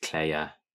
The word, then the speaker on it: Kleie: But yeah, it's pronounced Claire.